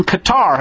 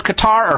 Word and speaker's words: Qatar: Note that Qatar, or Qatar, is another way to pronounce it. I've heard it both ways. Probably Qatar is more common.